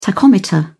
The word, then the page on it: tachometer: British English